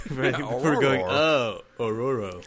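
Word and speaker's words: Ororo: I mean, you always say her name, but don't really until you read it as Aurora. – What? What are you talking about? – Yeah. What the hell? – Yeah, it's O-R-O-R-O. – Yeah, Aurora. – Aurora.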